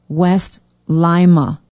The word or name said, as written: west lima